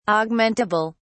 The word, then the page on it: augmentable: British English